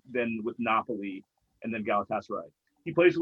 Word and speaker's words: Galatasaray: I think it's Galatasaray. – Yeah. – You're right. I think you're right, Dan. Yeah. – You can ask Justin Paul, but Dan is correct.